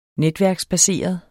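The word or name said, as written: NETVÆRKER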